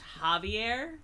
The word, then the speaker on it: Javier: I think it's pronounced Javier. – Is it a J? – Yeah. – Javier. – Javier. – J's or H's? – Javier.